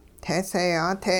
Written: teeseeyaa-te

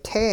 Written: -te

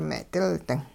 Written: me'dilding

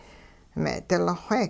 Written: me'dilxwe

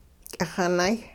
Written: k'ixinay

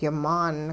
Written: yimaan

